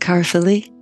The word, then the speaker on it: Caerphilly: I know very little about you, but I know, am I pronouncing it right? Is it Caerphilly? – Perfect.